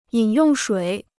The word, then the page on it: 饮用水: yǐn yòng shuǐ